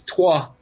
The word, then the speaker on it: Troyes: Rashi was born in the year 1040 in France, and he went to study in a town called Troyes, Troyes, it's called in French, is pronounced in French.